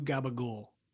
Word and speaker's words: Gabagool: It's at W Gabagool. – How do you spell Gabagool? – W G A B A G O O L. Gabagool. – So it's spelled out phonetically.